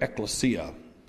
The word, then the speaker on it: ekklesia: The word church in the Greek, I think, is pronounced something like ekklesia.